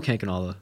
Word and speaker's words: Kankanala: It's Noah Kankanala.